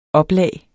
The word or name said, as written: oplag